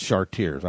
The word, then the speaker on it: Chartiers: Here, all we must do is replace Philippi with Cartier, oh, that's Chartiers, I was pronouncing it in the French, or Houston, Pennsylvania.